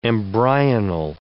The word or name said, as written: embryonal